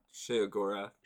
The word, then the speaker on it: Sheogorath: Or oh, Sh- it's like I think it's pronounced Sheogorath. Sheogorath. I thought it was She. Shograth. Meshuga. Sheogorath. That I mean, that's how he pronounces his name in Oblivion.